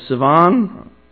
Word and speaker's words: Sivan: And based on that, if hopefully this is accurate, the third month is the month of Sivan, is how I pronounce it, which correlates to our June, and Ethanim is October, the seventh month.